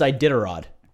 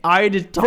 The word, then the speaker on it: Iditarod: In a Balto, it's Iditarod. – There, thank you. God, you came in. – Iditarod. – For once in your life, you've come in. – Okay. Yeah, baby. – Say it again. – Iditarod.